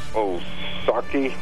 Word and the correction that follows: Ohtani: Shohei Ohtani. – Ohtani, yeah.